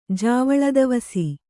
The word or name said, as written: ಜಾವಳದವಸಿ